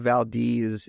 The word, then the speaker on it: Valdez: Like, there's, granted, the town of Valdez is spelled Valdez, and if you're from Hollywood, you're gonna see Valdez and say Valdez, but that's just not that's not how it's pronounced up here.